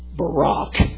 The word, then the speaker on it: Baruch: And, oh, by the way, Baruch can be pronounced Barak.